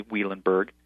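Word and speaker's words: Wielenberg: But Eric Weilenberg, and you pronounced it correctly, Weilenberg,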